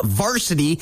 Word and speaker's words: VRSTY: It's all being brought to you by WDS, an AFC company, your largest Milwaukee tool dealer in Central Illinois, introducing you to a group called Varsity. It is spelled V-R-S-T-Y.